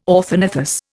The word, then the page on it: orthognathous: Indian